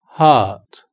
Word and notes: heart: British English

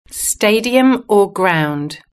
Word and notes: stadium: British English

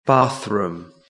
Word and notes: bathroom: British English

